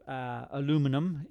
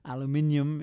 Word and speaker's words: aluminum: Aluminum is how I now pronounce it. Aluminium is how it used to be pronounced in Britain and still is by people who have not yet encapsulated the new. The spelling that is now uniform, which is aluminum.